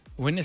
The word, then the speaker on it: Gwen: A ver, Gwen Stephanie se pronuncia Gwen... Para G-Wen.